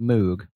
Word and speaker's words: Moog: It looks like it should be pronounced Moog, but it's actually Moog.